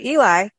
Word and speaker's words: Ely: I want to touch on the train because Yerington, which is where we came from to go through Ely.